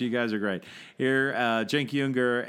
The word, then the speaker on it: Cenk Uygur: My friend Cenk Uygur, because I'm one of the three people in the world, apart from his parents, who know how to pronounce his name. – They pronounce his name right. – Cenk Uygur.